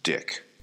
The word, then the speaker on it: Deuck: There was a large Mennonite population in Manitoba, and there was a woman whose last name was spelled D-E-U-C-K, and that is pronounced Dick. – Okay. – D-E-U-C-K.